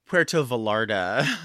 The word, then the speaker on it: Puerto Vallarta: I think it's actually pronounced Puerto Vallarta, actually. L's.